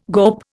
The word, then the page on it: GOP: Indian